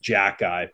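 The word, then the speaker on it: Xhekaj: His last name is pronounced Jack Guy, but he's Slovak. – Yeah. – It's spelled like X-H-E-K-R-A-J or something like that.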